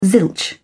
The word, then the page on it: zilch: British English